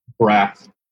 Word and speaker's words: Braff: I think I said that right. Braff. Braff? Braff? Braff? – Braff. – Zach Braff.